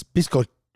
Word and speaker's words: biscochito: That's biscochito.